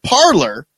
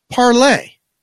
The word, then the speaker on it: Parler: They call it Parler. – Yeah. – But it's Parlay.